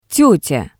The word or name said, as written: tetya